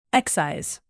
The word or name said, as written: excise